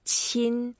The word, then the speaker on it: 亲: And the word qin, when we pronounce it, is the first tone. So we make it flat.